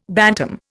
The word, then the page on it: bantam: Indian